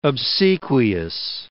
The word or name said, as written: obsequious